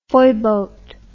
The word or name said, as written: foyboatman